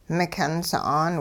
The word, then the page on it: mikyun sa'an: hupa